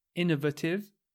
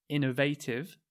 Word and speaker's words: innovative: The next word can be pronounced innovative or innovative.